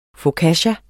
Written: focaccia

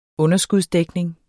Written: underskudsdækning

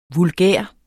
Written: vulgær

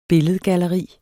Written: billedgalleri

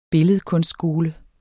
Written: billedkunstskole

